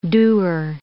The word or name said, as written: doer